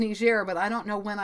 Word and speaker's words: Niger: The country is spelled N I G E R. Looks like Niger. I believe it's Niger, though.